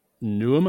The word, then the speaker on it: Neum: Just naming just a few on here, we've got Neum, I think it's pronounced, N-E-U-M, which is in Bosnia and Herzegovina.